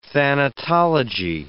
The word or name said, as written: thanatology